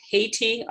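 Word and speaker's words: Hayti: One that I'm not familiar with is Haiti, almost like pronounced like the country Haiti, but it's H-A-Y-T-I in Durham, North Carolina.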